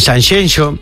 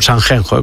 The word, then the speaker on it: Sanxenxo: y te cuento que este fin de semana en Sanxenxo, no Sanxenxo, como dicen. – Qué bien lo pronuncias, Sanxenxo, es como Shakira.